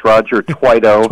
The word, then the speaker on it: Roger Tweedo: Hey, Quinn, first of all, you're going to have to learn how to say my name. It's Roger Tweedo. – Tweedo, okay. – Rhymes with righto.